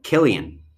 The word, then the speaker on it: Cillian: And the final one we have is C-I-L-L-I-A-N. Jillian? – It's a boy's name. – It's the C. – Killian. – Yes.